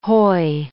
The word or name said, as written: hoy